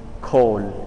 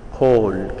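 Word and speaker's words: kol (קול): Un fragore, quasi un vento impetuoso, eh, la parola tuono per esempio torna in Isaia, la parola tuono, kol in ebraico, kol, eh, vuol dire voce, si pronuncia è una gutturale eh, profonda, col, che quasi avrebbe un effetto esplosivo come onomatopeica, col, col, che, che poi vuol dire anche tuono.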